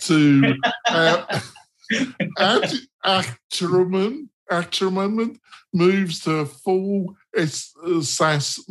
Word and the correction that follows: Atarim: So, first of all, I pronounce it some way. Atarim means websites in Hebrew, yeah, which is my native language. And that is what I was going for. But I don't want to force the pronunciation because in the English language, there is no emphasis on the end of the word. So Atarim doesn't exist in English.